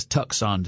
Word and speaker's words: Tucson: No, not T-U-S. You have to say it as Tucson to spell it correctly. It's T-U-C-S-O-N for no good reason.